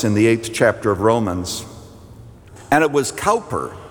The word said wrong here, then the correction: Cowper: Actually, I think to be pronounced Cooper, I think the English folks pronounce his name Cooper, William Cooper, who wrote regarding people, and this would certainly apply to atheists, wouldn't it?